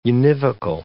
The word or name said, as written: univocal